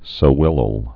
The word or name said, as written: sewellels